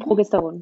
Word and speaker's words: Progesteron: Also Pro Progesteron, mhm. habe ich das richtig ausgesprochen? – Progesteron, genau.